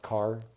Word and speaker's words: Kerr: I think Legacy by James, it's pronounced Carr, K-E-R-R.